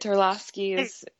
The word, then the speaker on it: Terlosky: Did I pronounce that right, Jenna? – You did. Terlosky isn't as hard as it looks.